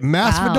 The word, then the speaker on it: Masvidal: Is it Masvidal? Wow. Is it Eat It All? – Yeah.